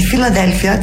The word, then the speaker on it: Φιλαδέλφια: Τη Φιλαδέλφια τη λέμε Φιλοδέλφια. – Φιλαδέλφια. Το goodbye το λένε goodbye.